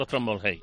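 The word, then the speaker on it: Szombathely: A partir de las 5 de la tarde, se dice así, Falco Strombolhei.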